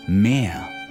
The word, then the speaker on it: mehr: Das Meer is the ocean, and Meer, M-E-H-R, translates to more. These two homonyms both have the long E sound.